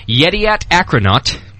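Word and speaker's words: Yedioth Ahronoth: Yediat Akronaut. Am I pronouncing that right, Pete? – Absolutely.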